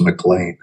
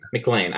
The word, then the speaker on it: McLean: By the way, the pronunciation is McLean. – McLean, I'm sorry.